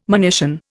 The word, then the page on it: monition: Indian